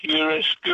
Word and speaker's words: chiaroscuro: It kind of starts that way a little bit, but it's an actual Italian word, so it's four syllables long. – Chiaroscuro?